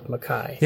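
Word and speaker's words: MacKaye: You heard me just say DJ McKay. I've been told that that's how he pronounced his last name. However, as you said, as we talked about earlier, I am Alec McKay.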